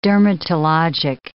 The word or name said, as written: dermatologic